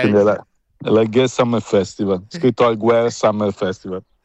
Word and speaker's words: Alguer: Ecco, vedi, Alger senza la R, si pronuncia senza.